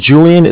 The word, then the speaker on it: Julian: My name is Julian. It's J-U-L-I-A-N. – Mm-hmm. – Calling with Bank of Cardiff. It's spelled C-A-R-D as in David, I-F-F as in Frank Frank.